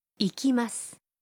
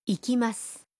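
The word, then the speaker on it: ایکیماس (行きます): ایکیماس. ایکیماس. – و اگر محلی که میریم رو به اون اضافه کنیم و بگیم به کینکاکوجی میرم میشه؟ – کینکاکوجی نی ایکیماس.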